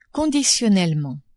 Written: conditionnellement

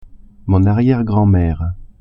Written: arriere-grand-mere

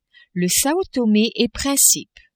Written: Sao Tome et Principe

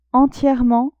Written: entierement

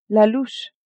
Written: louche

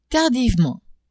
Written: tardivement

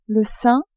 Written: sein

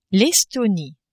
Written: Estonie

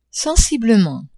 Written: sensiblement

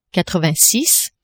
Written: quatre-vingt-six

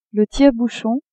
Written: tire-bouchon

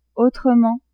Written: autrement

